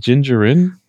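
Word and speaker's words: Din Djarin: Is it Gingerin? Gingerin? Dinjarin? Is it right? – Yeah. – I couldn't. – Gingerin. – I said it right the first time and you let me keep going.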